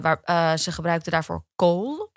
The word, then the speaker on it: kohl: K-O-H-L. – K-O-H-L. – Kool. niet gewoon K-O-O-L? – Nee, maar dat zou, dat zou kool.